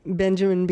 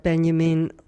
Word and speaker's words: Benjamin: and I pronounce it Benjamin because that's probably how most of our listeners will hear it, but Walter Benjamin.